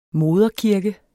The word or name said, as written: moderkirke